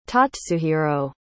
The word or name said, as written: tatsuhiro